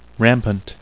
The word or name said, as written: rampant